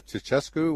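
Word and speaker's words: Ceaușescu: Ceausescu, was it? Is that, his, is that how you yes, say his name? Yes, yes, yes.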